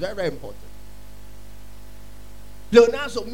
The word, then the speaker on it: pleonazo: Increase comes from the Greek word pleonazo. Pleonazo is spelled P-L-E-O-N-A-Z-O. P-L-E-O-N-A-Z-O.